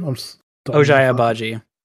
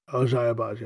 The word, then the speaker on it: Ochai Agbaji: Ochai Agbaji. – Ochai Agbaji.